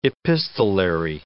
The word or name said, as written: epistolary